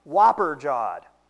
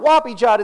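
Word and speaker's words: whopper-jawed: Come to find out, the actual slang word is pronounced whopper jawed, like Burger King Whopper. Whopper jawed, I looked it up. So this, of course, begs the question for Grandma. It's not whoppy jawed, it's whopper jawed.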